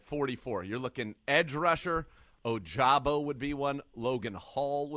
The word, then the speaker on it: Ojabo: If you see Ojabo, that's the one we want to be able to get that pronunciation right. – Ojabo.